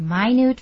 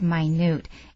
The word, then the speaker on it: minute: This 10-part series is packed with action as it explores dozens of minute facts about everyday items. Look at the word minute. If you're not paying attention, you might say minute. That's spelled the same.